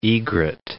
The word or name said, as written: egret